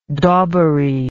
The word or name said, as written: daubery